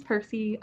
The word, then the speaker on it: Percie: Yeah, my name's Percy. Two syllables, six letters.